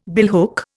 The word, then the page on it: billhook: Indian